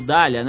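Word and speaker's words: Dhalia: Foi a personagem título do filme Nina, de Heitor Dalia. Atual, Dália, né? Acho que é Dália a pronúncia. Dália.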